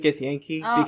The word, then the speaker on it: Githyanki: Guys, can we pronounce it Githyanki? Because that's how the actual Dungeons & Dragons podcast refers. – Githyanki? – I hate that. That's like saying Jif.